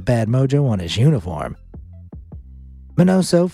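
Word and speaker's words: Miñoso: From stinky feet to white socks now, Minnie Minoso, pronounced Minoso,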